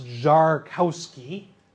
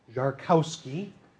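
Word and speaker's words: Szarkowski: Properly pronounced Czarkowski, Czarkowski, he was first and foremost a photographer.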